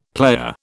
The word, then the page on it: playa: British English